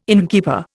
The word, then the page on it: innkeeper: Indian